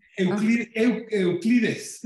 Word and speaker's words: Euclid: ¿Cómo, cómo lo pronunciaríamos? – que ah, son... – Euclides. Euclides, que es un satélite que está totalmente diseñado para tratar de entender esta energía oscura que platicábamos antes.